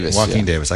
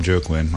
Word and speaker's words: Joaquin: Is it Joaquin Davis? Where's our pronunciation? – Joaquin Davis. – I called him Joe Quinn.